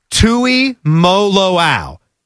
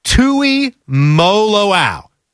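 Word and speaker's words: Tuimoloau: But by the way, in the final few seconds, Patrick Murphy, our buddy who comes on every Friday, the official pronunciation, because he's official now, it's JT Tui Moloau. That's the exact pronunciation. Tui Moloau.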